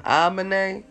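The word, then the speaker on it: Aminé: Amani, how you pronounce his name? Amine, man, A-M-I-N-E.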